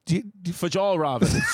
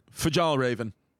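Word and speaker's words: Fjällräven: It's spelled F-J-A with the two dots over it, L-L-R-A with the two dots over it, V-E-N. Fajal Raven. – Okay. You're pretty close, actually.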